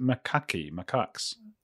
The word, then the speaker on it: macaques: Tell me about like mirroring, and how do you pronounce these monkeys that I've read the name of them for like my whole life and I never had to pronounce them? Is it macaques? – Yeah.